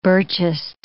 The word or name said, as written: Birchist